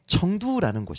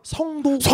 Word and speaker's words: 청두: 유비 관우 장비 그 총나라의 수도가 스촨성의 청두라는 곳입니다. 스촨. – 아 그래요? – 네. – 청두. – 네, 네, 한국어 발음 성도.